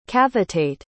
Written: cavitate

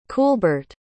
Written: Kuhlbert

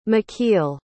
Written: mikeal

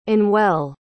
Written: anwell

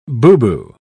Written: boubou

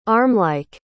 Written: armlike

